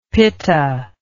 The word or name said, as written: pitta